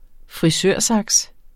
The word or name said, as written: frisørsaks